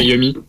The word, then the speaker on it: Yummy: C'est Yumi. – Yumi, Yumi, ouais, ok. Donc c'est Yumi qui va arriver dans la crois, je crois.